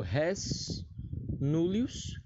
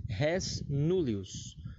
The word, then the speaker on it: res nullius: Mas enfim, continuando, o furto abigeato ele se divide aqui em dois: o res nullius, que se escreve R-E-S, aí separa N-U-L-L-I-U-S. Res nullius.